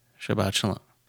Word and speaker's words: Shabbat Shalom: Shabbat Shalom.